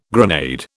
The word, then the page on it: grenade: British English